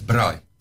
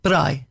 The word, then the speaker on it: braai: How do we say it properly, braai? – It's braai. – Braai.